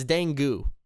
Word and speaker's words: Dangu: So it's Dangu. – Rhymes with dew. – Mountain Dew. All right, sure. – Or like the dew that comes on the grass in the morning.